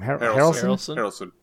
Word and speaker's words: Harrelson: It was Matthew McConaughey, Woody, how do you say his last name? – Harrelson. – Woody Harrelson, co-stars, investigating a gruesome murder in Louisiana along the bayou.